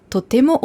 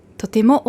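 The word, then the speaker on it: totemo (とても): Vorsicht bei der Aussprache. Die Betonung ist auf dem T. Nicht auf dem To. Also nicht totemo, sondern totemo.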